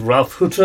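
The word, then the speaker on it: Ralf Hütter: Is that last week we put in an interview with Ralph Hutter of Kraftwerk.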